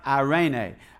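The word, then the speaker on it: eirene: It's E-I-R-E-N-E, but it's pronounced irene.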